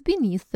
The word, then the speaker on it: beneath: Or, for example, we huddled together for warmth beneath the blankets. We huddled together for warmth beneath the blankets. Beneath. Особенно вот это произношение в конце, th-th-th, любимый звук всех русскоговорящих, всегда всех пугает.